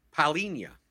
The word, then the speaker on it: Paulinho: playing someone like Paulinho. Paulinho, that's how you pronounce it.